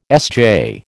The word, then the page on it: SJ: American English